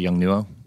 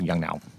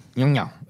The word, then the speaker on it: Yongnuo: Yung Niao? – I think in English we call it Yung Nuo, but I think the proper lens is Samsung Yung Niao. – Yung Niao, okay.